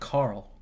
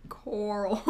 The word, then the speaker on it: Coral: No, not Carl. – Coral. You gotta pronounce it like you're saying the word coral. Like the sea thing.